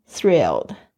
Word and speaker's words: thrilled: I'm thrilled，就是这个 thrilled 就指非常非常兴奋哦，哦，特别注意一下你们 th 的发音 thrilled，还有 l 的发音哦，thrilled 这个字不是太好念，就是要特别注意一下 th 跟 l 的发音 thrilled。